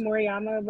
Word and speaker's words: Moriyama: It's pronounced Moriyama, but you can call me Mori for short.